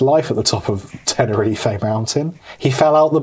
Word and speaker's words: Tenerife: Tenerife. – That's how they say it there.